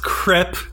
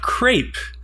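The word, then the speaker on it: crêpe: It's pronounced crepe, not crepe.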